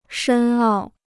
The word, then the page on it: 深奥: shēn ào